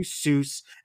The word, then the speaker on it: Seuss: Around the same time, Geisel changed the pronunciation of his pen name to Seuss, as it was more familiar to children as it rhymed with Mother Goose.